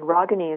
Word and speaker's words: Ragini: And Rogani is spelled R-A-G-I-N-I.